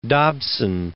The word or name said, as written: Dobson